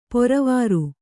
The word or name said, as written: ಪೊರವಾರ್